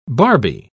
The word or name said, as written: barbie